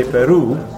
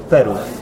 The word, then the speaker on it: Peru: Now, we should actually remind our listeners that the stress in the word Peru is on the second syllable, so we say Peru. Instead of Peru.